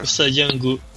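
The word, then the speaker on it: Usa Jingu: Mano, o santuário de Usa Jangu, eu não sei falar essas coisas, me ajuda aí com a pronúncia. É o Jangu, é isso aí? Jungu? – Sa, hum. – É, é. Usajangu. Aí, caralho, tô. – Usa Jangu. – Usa Changu. É, é. Os caras.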